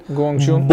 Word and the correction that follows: Bong Joon-ho: Bong Joon Ho.